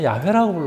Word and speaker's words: YHWH: 근데 이제 학자들은 우리는 여호와라고 부르지만은 미국 사람들은 야훼라고 불러요. 야훼하고 발음이 비슷할 거라고 그랬어요.